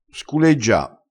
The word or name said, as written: sculeggia